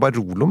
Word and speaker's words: Barolo: Jeg sier jo Barolo, men hvis man skal uttale riktig italiensk uttale, så er det Barolo.